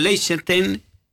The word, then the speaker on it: Liechtenstein: Ya lo que contamos sin CR7, Irlanda, Austria, Israel, Kosovo, Azerbaiyán con Liechtenstein, ahí está, lo dije bien.